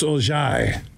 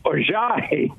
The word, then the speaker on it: Orji: And I think his real name, I'm looking at the enunciations and pronunciation guide for Michigan football. It's Ojai. – Ojai.